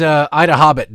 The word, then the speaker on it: IDAHOBIT: Today is Ida Hobbit Day. Ida Hobbit Day. And that's not somebody saying, you know, I'm the Hobbit. You think you're the Hobbit. No, I'm the Hobbit. It's spelled that way. It's spelled I-D-A-H-O-B-I-T.